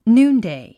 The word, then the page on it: noonday: núːndèi; ヌーンデイ